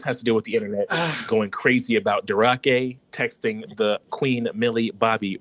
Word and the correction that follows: Drake: Yeah, Drake. – Oh, the bitch said Durake. Like, bitch. – Yeah, you gotta say the name right.